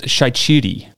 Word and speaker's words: Shaqiri: I met a guy from Albania who told me that Shaqiri's last name is pronounced Shaichiri, which is a fun fact.